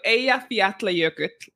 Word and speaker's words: Eyjafjallajökull: But yeah, so Eyjafjallajökull. – Eyjafjallajökull.